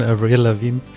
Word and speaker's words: Avril Lavigne: Da, da, da, wie geht das Lied von Avril Lavigne?